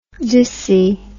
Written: dissy

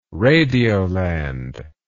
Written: radioland

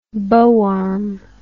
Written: bow arm

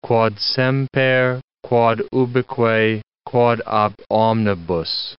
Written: quod semper, quod ubique, quod ab omnibus

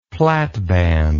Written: platband

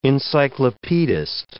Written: encyclopedist

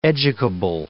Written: educable

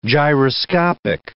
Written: gyroscopic

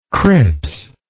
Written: Cripps

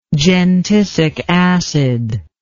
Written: gentisic acid

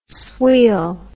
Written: sweal

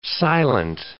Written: silent

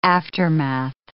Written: aftermath

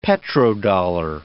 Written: petrodollar

